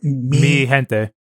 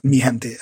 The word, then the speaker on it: Mi gente: Mi gente. – It's the G is like, G is an H in Spanish. – Okay. Mi gente.